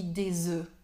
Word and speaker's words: œufs: Alors, si je peux corriger la prononciation un tout petit peu. – Oui, les œufs, on dit un œuf, mais quand c'est pluriel, on dit des œufs.